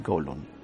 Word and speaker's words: Kowloon: Yo voy a decir Kowloon, ¿vale? Porque es como lo pronunciaríamos nosotros, aquí los españoles.